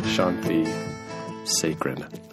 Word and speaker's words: Shanthi Sekaran: Shanthi Sekaran.